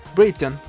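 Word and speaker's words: Brereton: Como lo dijo la mamá en una entrevista, le preguntaron cómo, cómo se pronuncia Bereton Y dijo, no, no se pronuncia breton se pronuncia Breton. Brighton, Brighton,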